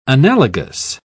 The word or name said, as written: analogous